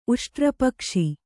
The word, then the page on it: ಉಷ್ಟ್ರಪಕ್ಷಿ: Kannada